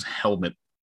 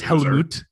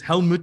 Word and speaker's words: Helmut: I'll also interject and say his name's Helmet. – Yeah, no. No, it's Helmut. Helmut. Helmut. His name is Helmut Zemo.